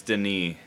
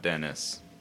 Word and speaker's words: Denis: But it's Denis, not Dennis. – Okay. – I learned that in the special features of this movie.